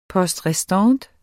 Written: poste

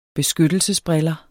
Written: beskyttelsesbriller

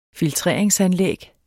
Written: filtreringsanlæg

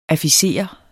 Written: afficere

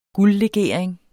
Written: guldlegering

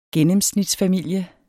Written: gennemsnitsfamilie

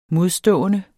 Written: modstående

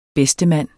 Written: bedstemand

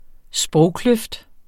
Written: sprogkløft

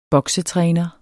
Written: boksetræner

